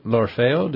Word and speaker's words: L'Orfeo: So it's Monteverdi's L'Orfeo. Did I say that right? – Oh, that's perfect.